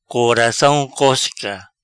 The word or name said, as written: Coracam Cosca